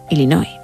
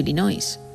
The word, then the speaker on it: Illinois: Habló del estado en el que nació el Papa León XIV, que es Illinois. Podría haber dicho Illinois. Podría haberlo dicho bien, Illinois, o Illinois incluso.